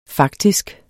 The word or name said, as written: faktisk